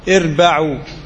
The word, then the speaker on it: اربعوا: ونطق الكلمة كما ضبطها الحافظ في الفتح: اربعوا على أنفسكم، اربعوا مش اربعوا أو اربعوا، اربعوا.